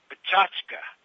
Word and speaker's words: Pecha Kucha: And I have to say this, it's the proper way of pronouncing it is Pachachka. But it's also, but the way it looks is they started in Japan, so it's this Japanese thing. It looks like Pecha Kucha, but it's pronounced Pachachka, right?